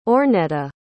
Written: ornetta

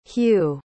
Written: hugh

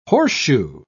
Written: horseshoe

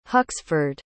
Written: Huxford

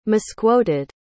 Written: misquoted

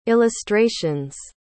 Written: illustrations

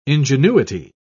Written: ingenuity

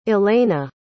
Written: ilena

